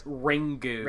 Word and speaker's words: Ringu: Corpse Party is contemporaneous with Kuon and Ringu. That's how you pronounce it, right? In the original? – I believe so, Japanese. – Yes. – Ringu.